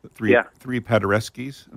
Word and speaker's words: Paderewskis: What the message is in this new production, Three Paderewskis.